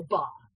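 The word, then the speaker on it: Bá: Ba. Which I hope I pronounced that last name right. It's Spanish, and their accent is on the A.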